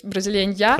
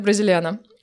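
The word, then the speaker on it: Brasileña: Это испанский язык, там, где N с черточкой сверху, это читается как «я». Ну, то есть «бразилень-я». – Хорошо. В основном говорят «бразилена».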